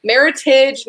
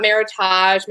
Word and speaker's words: Meritage: Meritage, not Meritage. Meritage is the proper way to say it.